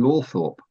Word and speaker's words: Althorp: I wrote to the new Earl, Charles Spencer, and got a letter back saying, yes, Althorp was definitely the pronunciation used by the family.